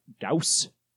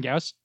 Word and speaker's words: Gauss: Wait, isn't that Gauss? – Yes, that's typically how you pronounce it. – Like, as in the guy's last name? – Johann Gauss? – Yeah.